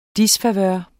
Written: disfavør

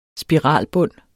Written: spiralbund

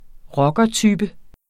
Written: rockertype

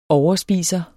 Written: overspiser

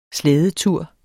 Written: slædetur

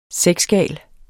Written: sexgal

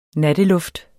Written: natteluft

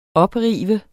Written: oprive

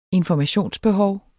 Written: informationsbehov

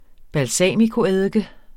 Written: balsamicoeddike

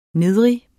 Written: nedrig

